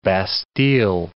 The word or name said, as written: bastille